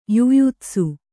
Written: ಯುಯುತ್ಸು

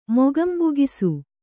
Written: ಮೊಗಂಬುಗಿಸು

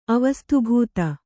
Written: ಅವಸ್ತುಭೂತ

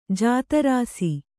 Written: ಜಾತರಾಸಿ

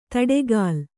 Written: ದಾಟುಗಾಲು

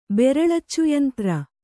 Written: ಬೆರಳಚ್ಚುಯಂತ್ರ

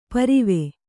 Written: ಪರ್ವು

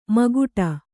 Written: ಮುಕ್ಕೋಟಿ

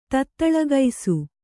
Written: ತತ್ತಲಗೂಸು

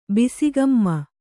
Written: ಬಿಸಿಗಮ್ಮ

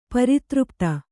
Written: ಪರಿತೃಪ್ತೆ

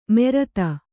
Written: ಮೆರತ

